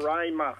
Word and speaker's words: Roraima: That's spelled R-O-R-A-M-A. – A-I-M-A. – A-I-M-A, is it? – Rama. R-O-R-A... – I-M-A. – Hang on. R-O-R... – A-I-M-A. – A-I-M-A.